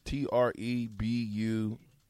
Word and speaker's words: trebuchet: That's a big word for me, Trubuchet. Did I say it right? Trubuchet? – Close enough. – Trubuchet, Trebuchet.